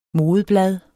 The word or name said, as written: modeblad